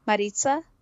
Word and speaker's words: Maritza: Did I say that right, Maritza? Mm, it's close. – close. Yeah, it's like really good. I mean, I think that's we don't really give enough credit to the people they hit close, you know? – Yeah, it's okay. – Well, how would you We're say it? There. How would you say it? – Maritza?